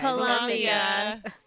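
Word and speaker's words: Pilamaya: How do we pronounce it? – Palamia. – How do we say thank you? Look at you guys. – Palamia.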